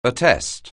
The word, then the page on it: attest: anglais